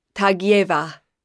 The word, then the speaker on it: Tagieva: Tagieva.